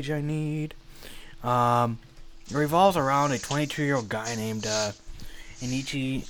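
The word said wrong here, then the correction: Eikichi: Onizuka. – Ikichi. – That's how you say it. Ikichi.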